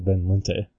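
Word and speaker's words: Van Lente: Oh, I found out that Fred Van Lint's name is Fred Van Linte.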